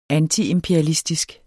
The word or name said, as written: antiimperialistisk